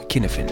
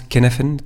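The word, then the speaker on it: Cynefin: Euh, ça s'écrit C-Y-N-E-F In n quelque part on pourrait le prononcer en, français à la française, mais ça se prononce Canafen Canaven c'est,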